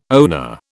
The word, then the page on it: oner: British English